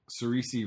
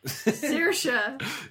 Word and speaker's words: Saoirse: So their actress, I'm not even going to say her name right, Cerise Ronan. She's Irish. – Saoirse. – Saoirse. Oh, sorry. I didn't live in Ireland for 2 years. – Yeah. – What's her name? – Saoirse.